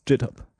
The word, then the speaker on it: GitHub: Ja, yeah, it's pronounced GitHub.